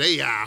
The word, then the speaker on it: Ai: I know it's got a hyphen or whatever you call it over the I. I guess you're supposed to pronounce it a long I. So we're going to call it AI, all right?